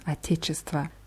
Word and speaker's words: Отечество: Отечество endet auf O am Ende, wird aber mit A ausgesprochen. Отечество.